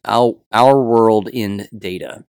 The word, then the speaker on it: Our World in Data: So says the Our World India data. I'm saying that correctly. Our World in Data. There we go.